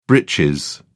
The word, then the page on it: britches: British English